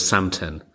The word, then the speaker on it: Samten: I've got a mindfulness app that's called Samten. – Samten. Can we spell that? – S-A-M-T-E-N. Sam like Sam, the name, and ten as in number 10.